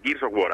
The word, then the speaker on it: Gears of War: Y sí, ahora que era por Game Gears. O sea, bueno, que. O sea, Gears of War, ahora lo he dicho bien, maldita dislexia.